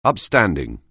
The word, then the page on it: upstanding: anglais